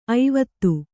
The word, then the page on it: ಐವತ್ತು: kannada